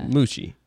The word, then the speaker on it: Moochie: It's spelled like Gucci, but with an M, so I'm going to say Moochie.